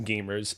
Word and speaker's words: GVMERS: So quickly, before we start, I just want to mention the YouTube channel is pronounced Gamers. It's spelt G-V-M-E-R-S. Mm-hmm. Basically what we do is we stylize the V so that we flip it upside down so it looks like an A, hence how you, mm-hmm.